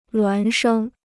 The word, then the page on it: 孪生: luán shēng